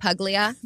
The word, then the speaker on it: Puglia: So, you need to let him know that it's actually pronounced Puglia.